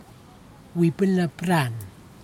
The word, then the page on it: napiran: kwamera